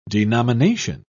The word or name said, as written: denomination